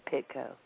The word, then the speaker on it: Pitko: I just got involved in the case when you guys posted the letter and mentioned Joey Pitko, and that's how you pronounce his name, Pitko.